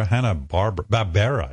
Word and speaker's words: Hanna-Barbera: Was it Hanna-Barbera or Hanna-Barbera, I guess.